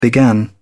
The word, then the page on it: began: British English